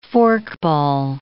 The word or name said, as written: forkball